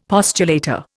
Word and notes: postulator: Indian

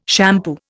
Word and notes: shampoo: Indian

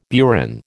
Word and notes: burin: American English

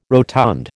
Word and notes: rotund: American English

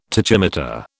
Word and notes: tachymeter: British English

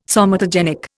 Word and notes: somatogenic: Indian

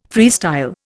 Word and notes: freestyle: Indian

